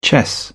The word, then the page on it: chess: British English